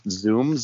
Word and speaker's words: Zum: Well, my underwear drawer, do you guys know, I think it's pronounced Zoom, Z-U-M soap.